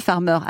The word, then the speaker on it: FARMR: Alors, ça se dit Farmer à l'anglaise, mais ça s'écrit F-A-R-M-R.